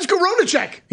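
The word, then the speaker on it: Skowronek: Ben Skoronacek, he's back.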